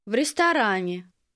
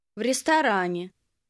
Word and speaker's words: в ресторане: В ресторане. В ресторане. – Did you hear the v sound at the beginning of the word for restaurant? This sound and the ending are what mean in the.